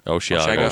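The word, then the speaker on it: Osheaga: That's how you say it, Oshiga. – Yeah, Oshiaga.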